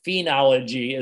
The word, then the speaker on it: phenology: And they were referring to various kinds of words that, like fin, as in studying fins of a fish, or something like that. So no, phenology is the way I pronounce it.